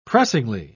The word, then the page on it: pressingly: British English